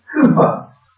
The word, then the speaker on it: Cowper: Spelled Cowper, but I'm reliably told to pronounce Cooper. So I'm going to call him Cooper throughout.